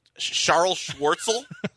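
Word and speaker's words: Charl Schwartzel: I'm not 100% sure how this pronounced. Charles Schwartzel? – That's how it's pronounced, yeah.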